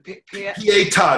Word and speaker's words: Pietà: Pietà, it's spelled P-I-E-T-A.